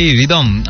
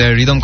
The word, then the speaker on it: Rythom: আপনি যেটা লিখেছেন RIDOM রিদম, এটা হবে না, আমার বানানটা হচ্ছে RYTHOM টি, আমরা যে চা খাই টি সেই টি আর কি, RYTHOM। আর যে রিদম, এটা আমার একটা ছোটোখাটো পেজ আছে, অ্যান্ড রিদম খন্দকার এটা হচ্ছে আমার প্রোফাইল।